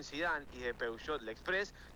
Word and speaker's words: Peugeot: Porque no se dice Peugeot, se dice Peugeot.